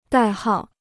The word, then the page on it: 代号: dài hào